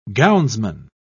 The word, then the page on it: gownsman: British English